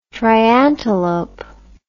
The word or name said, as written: triantelope